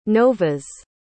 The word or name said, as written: nova's